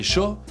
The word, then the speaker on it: ch'or: D'ailleurs, pour bien prononcer « ch'or », c'est un subtil mélange entre « chat » et « chaud ». Ça donne « ch'or ».